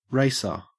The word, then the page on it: racer: Australian English